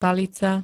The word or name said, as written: palica